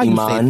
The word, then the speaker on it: Iman: And then keeping the teapot boiling, Amon Schubert. Now, am I saying his name wrong? I know I'm saying it. Y'all know me. I butcher a name. How Iman. – You say Iman. And is it Schubert? – I don't know. This is the first time that I'm hearing about this person. – No, you lying. – I don't watch Dancing with the Stars. – I mean, I don't watch Dancing with the Stars either, but I know Iman. I'm saying it right.